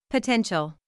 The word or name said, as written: potential